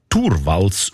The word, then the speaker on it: Torvalds: Der Linus Torvalds, eigentlich Torvalds ausgesprochen, damals Informatikstudent Helsinki, er hat die wertvollste Software der Welt namens Linux einfach verschenkt.